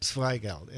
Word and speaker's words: Zweigelt: But the first one, did you say Zweigelt? – Zweigelt, and it's spelled Z-W-E-I-G-E-L-T.